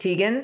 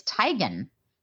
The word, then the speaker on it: Teigen: Chrissy, how do you say Chrissy? The last name, you know who I'm talking about. Chrissy. – Teigen. – Blank. Yes, it's Teigen.